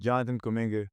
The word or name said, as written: Jonathan Kuminga